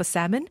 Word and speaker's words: salmon: I was something like, 28 years old when I found out the proper pronunciation for salmon. Before that, I had been saying the L constantly because it was a word that I'd read more than I'd said.